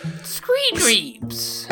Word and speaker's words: Screedreeps: Screedreeps. – S-C-R-E-E-D-R-E-E-P-S.